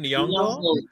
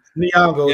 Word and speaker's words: Nyong'o: Nyong'o. – Nyong'o, yes.